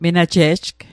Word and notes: menaje'jg: Mi'gmaq

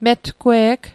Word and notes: metgwe'g: Mi'gmaq

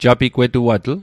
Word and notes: japigwetuatl: Mi'gmaq

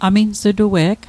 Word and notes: amins'tue'g: Mi'gmaq

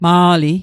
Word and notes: Ma'li: Mi'gmaq

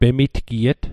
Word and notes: pemitgiet: Mi'gmaq

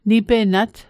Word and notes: nipenat: Mi'gmaq

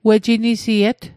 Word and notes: wejinisiet: Mi'gmaq

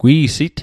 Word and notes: gwi'sit: Mi'gmaq

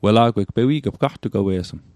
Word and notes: gaqtugwewe'sm: Mi'gmaq

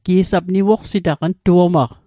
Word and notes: niwoqsit: Mi'gmaq

